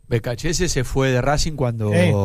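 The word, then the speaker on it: Beccacece: Ahora es Becachese. – Tenemos que unificar criterios. Tiene que ahora viene el Mundial, hay que pronunciar todo parecido.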